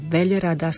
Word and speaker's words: veľrada: A na konci, tam tá veľrada sa veľmi ťažko vyslovuje. Veľrada. Pozor, pomaly.